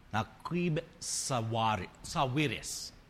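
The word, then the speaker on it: Naguib Sawiris: His name, if I can pronounce it correctly, Naqib Sawiris. not Quim Sawiris.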